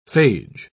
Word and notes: phage: British English

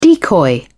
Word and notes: decoy: British English

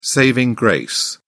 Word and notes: saving grace: British English

